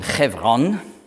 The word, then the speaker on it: Hebron: Hebron, hebräisch ausgesprochen Chevron, heißt Gemeinschaft.